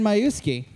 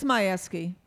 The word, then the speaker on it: Majewski: Well, everyone, please welcome our next guest, the mayor of Hamtramck, Karen Majewski. I said it wrong again. I think I said it wrong again. Hello. – Hello. – Welcome. – It's Majewski.